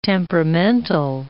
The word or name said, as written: temperamental